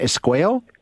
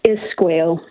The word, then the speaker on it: Esquao: How do we say that? Esquao. Esquao. Yep. – Okay, I got correct. that one right.